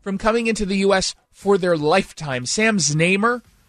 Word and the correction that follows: Znaimer: is a Canadian, and is that how you say it? It's Znaimer.